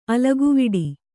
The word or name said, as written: ಅಲಗುವಿಡಿ